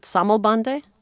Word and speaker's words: Sammelband: This other common practice of the time, and I don't know how to pronounce it, is it Sammelbande? What is Sammelbande? – Yeah, so the Sammelband, the kind of the gathering of books bound together.